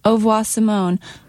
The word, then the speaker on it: Au Revoir Simone: I usually immediately, people look perplexed, so I usually immediately say Au Revoir Simone. Au revoir, like goodbye in French.